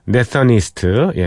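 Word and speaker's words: Nathan East: 준비한 그 yesterday 버전은요, 예, 베이시스트인 네서니스트, 예, 네이선니스트라고도 발음하기도 하네요.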